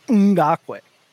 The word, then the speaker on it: Ngakoue: Colts defensive end Yannick Ngakoue Ngakoue. I love names where you say just the N sound, where you go Ngakoue.